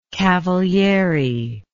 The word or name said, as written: Cavalieri